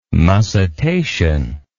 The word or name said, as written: mussitation